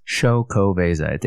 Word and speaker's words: Xocoveza: That's with an X at the beginning. I hope I'm getting the pronunciation somewhat correct. Yeah, it says Xocoveza. It even tells you how to pronounce it on the can.